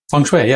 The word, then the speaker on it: feng shui: Feng shui, yeah.